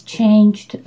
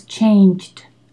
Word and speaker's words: changed: He doesn't pronounce the D of the verb to change, and that's very important, okay? At the end of Intermedia 2, you should be very good at pronouncing the ED ending. So, but now her life has changed a lot. Changed a lot. Do a T. If you cannot do a D, do a T, okay?